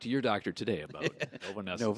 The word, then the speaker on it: Novonesis: You're currently an account manager for Novanesis. – Novanesis. – Yes. Did I pronounce that right? You did. Okay. It is not Novonis. It is not Novanis. It is not Novenison.